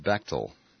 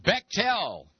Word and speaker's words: Bechtel: I've been saying Bechtel Corporation. It is Bechtel. Bechtel Corporation. Not Bechtel. Bechtel. All right. We stand corrected. Also, an email from Ireland, from Colm, C-O-L-M.